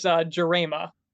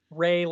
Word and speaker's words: Jarema: Yeah, actually, if you remember at the captain's match in Frankenmuth last year, I gave you the guidance on pronunciation of Ray, like Ray of Sunshine, and then you started calling me Ray of Sunshine the rest of the night.